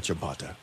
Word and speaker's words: Champeta: Is it Champeta or Champata? – Champeta. – Champeta.